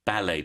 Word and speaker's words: ballet: So first we have ballet dancer. Ballet. Then we have belly dancer. Belly. So you can hear the difference in the sounds of the vowels.